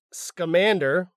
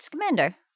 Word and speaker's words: Scamander: And Newt Scamander, the name of that film's hero.